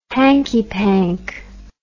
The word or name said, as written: hanky-pank